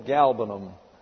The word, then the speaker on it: galbanum: The third is galbanum. G-A-L-B-B-A-N-U-M. The accent on the first syllable, galbanum.